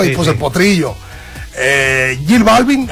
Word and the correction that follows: J Balvin: así se pronuncia Jill Balvin. – Jay. – Yo prefiero preguntar para luego regalarla con otro. Jay Balvin. Ok, pues es que nomás dice una J, pues, o sea.